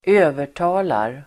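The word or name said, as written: övertalar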